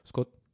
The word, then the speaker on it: Grüß Gott: Nee, die sagen, grüß Gott. – Grüß Gott. – Übrigens, wird nie ganz ausgesprochen, ist immer nur, Scott.